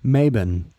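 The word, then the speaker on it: Mabon: And, you know, we'll get there once we start talking about Mobin or Amabin. I'm sorry, that's the festival, the pagan festival we're about to come to at the equinox. It was named like in the 70s with the neo-pagans. They pronounced it Mabin.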